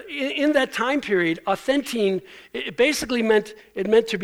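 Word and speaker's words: authentine (αὐθεντεῖν): So it's like Ovaltine, but the transliteration, meaning the English pronunciation of a Greek word, authentine is used one time in the entire New Testament, and it's used by Paul in this situation.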